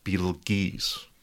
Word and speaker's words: Betelgeuse: I swear up and down, and there's a couple other internet denizens that agree with me, that before the movie Beetlejuice came out, it was commonly pronounced Beetlegeese.